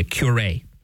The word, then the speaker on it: curé: which in French would be the curé. See, it's spelled like the word cure, C-U-R-E. There's a little accent over the E. It's pronounced curé. He He's made the curé of a small village called Ars, A-R-S.